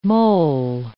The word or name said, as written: mol